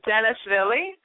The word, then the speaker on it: Janashvili: It's a very complicated Georgian surname. It's Janashvili, and it pretty much nobody can pronounce it well.